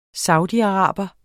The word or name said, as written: saudiaraber